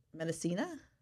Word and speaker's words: Medicina: How do you say the second part? Medicina? – Yes.